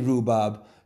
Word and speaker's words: rhubarb: So if you say rhubarb, you don't really say the H, but you know it's there, you know it's snuggled up against the R, and I was just, this is serious, this is genuine, much like the bobble hat with a bobble growing on my head.